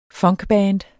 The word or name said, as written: funkband